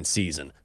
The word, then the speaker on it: SZN: S-Z-N. Do you pronounce it that way or do you still say season? Or is it season? – Season.